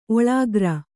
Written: ಓಲೆಕಾರ

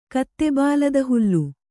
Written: ಕತ್ತೆಬಾಲದಹುಲ್ಲು

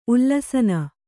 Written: ಉಲ್ಲಸನ

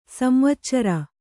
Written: ಸಂವಚ್ಚರ

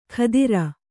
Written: ಖಾದಿರ

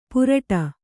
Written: ಪುರಟ

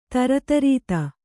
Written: ದರಿದ್ರತೆ

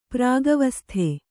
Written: ಪ್ರಾಗವಸ್ಥೆ

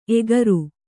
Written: ಏಕಾರ

